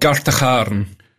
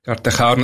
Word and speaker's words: Gartacharn: Yeah, so the village I was brought up in is called Gartacharn. Gartacharn, it's got that lovely Scottish ch sound, the ch as in loch.